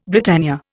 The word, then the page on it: Britannia: Indian